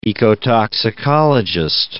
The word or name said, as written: ecotoxicologist